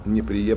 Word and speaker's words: Неприе: Произношение я Неприе правильное, потому что я воевал на Калининском фронте, в этой деревне жил. Ручаюсь за ударение.